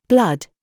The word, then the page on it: BOB: British English